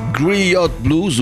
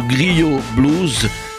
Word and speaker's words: Griot: C'est l'album euh, Griot Blues ou Griot Blues, comme vous voulez, si vous le prononcez en anglais ou en français.